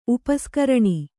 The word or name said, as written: ಉಪಸ್ಕರಣಿ